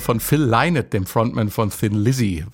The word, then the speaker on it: Lynott: Die ARD-Aussprachedatenbank hat sich bei der Aussprache an den Kollegen der BBC orientiert. Und die Damen und Herren auf dieser Insel sagen laut BBC-Aussprachedatenbank Lynott hier zu hören.